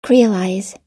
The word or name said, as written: creolize